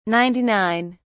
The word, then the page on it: ninety-nine: British English